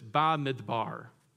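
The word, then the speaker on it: Bamidbar: In Hebrew, it's pronounced like this, Ba-Midbar, which means in the wilderness.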